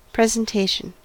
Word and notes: presentation: American English

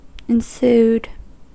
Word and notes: ensued: American English

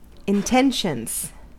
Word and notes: intentions: American English

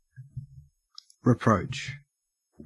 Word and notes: reproach: Australian English

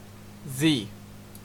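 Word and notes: zee: Canadian English

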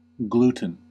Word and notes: gluten: American English

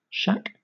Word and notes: shack: British English

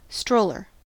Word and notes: stroller: American English

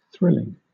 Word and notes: thrilling: British English

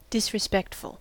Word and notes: disrespectful: American English

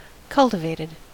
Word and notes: cultivated: American English

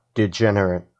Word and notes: degenerate: American English

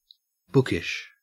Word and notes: bookish: Australian English

